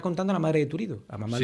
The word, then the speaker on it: Turiddu: Lola y Tirutdu se aman. Y yo lloro, lloro. A ver, ¿cómo se llama? Yo no lo pronuncio bien. – Lola y Turiddu, sí, está bien.